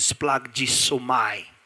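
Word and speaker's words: splagisomai (σπλαγχνίζομαι): And even the word cannot properly be pronounced. It is splagisomai. And it's splagisomai.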